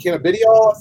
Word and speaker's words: cannabidiol: They are energy shots, essentially, and relaxation shots that use what's called cannabidiol, I think.